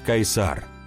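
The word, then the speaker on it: Caesar: Царя, который суть Цезарь, или, если правильно говорить, Кайсар, и который в старинной для Рима традиции является либо членом семьи монарха, либо младшим соправителем Августа, сиречь императора.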